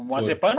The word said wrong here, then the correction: Mazepin: O Mazepin, o, o Will tá falando certo, não é pano, é pin.